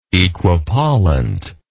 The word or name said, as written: equipollently